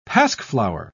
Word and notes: pasqueflower: British English

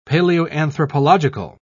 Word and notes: paleoanthropological: British English

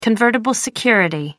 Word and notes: convertible security: American English

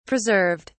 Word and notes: preserved: British English

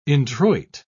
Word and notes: introit: British English

